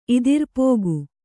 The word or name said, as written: ಇದಿರ್ಪೋಗು